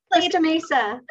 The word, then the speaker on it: Costa Mesa: Costa Mesa.